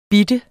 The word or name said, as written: bitte